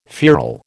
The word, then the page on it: feral: American English